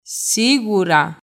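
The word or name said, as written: Σίγουρα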